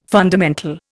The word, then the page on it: fundamental: Indian